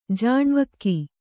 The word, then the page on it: ಜಾಣ್ವಕ್ಕಿ: jāṇvakki